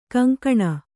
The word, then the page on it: ಕೊಂಕಣೆ: koŋkaṇe